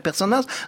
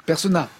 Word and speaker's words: Personnaz: Comme il me disait, Père Albert, c'est bizarre, j'ai jamais encore vu Laetitia Casta et Raphaël Personnaz dans scène de ménage. – Persona, pas Personnaz.